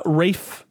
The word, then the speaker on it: Ralph: And uh, Rafe, I, I believe that's how you say his name. Ralph Fiennes.